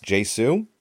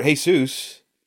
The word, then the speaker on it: Jesu: I did confirm that his name is actually pronounced Jesu. – Hi, my name is Jesu Garcia. – It's short for Jesus, but he pronounces it Jesu, so.